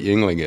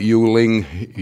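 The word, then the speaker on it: Yuengling: So that, but we're getting a pretty good reputation now, and people know what Yingling is. It amazes me. – What are some of the mispronunciations you've heard? – Well, they'll call it Yuling, Younglings.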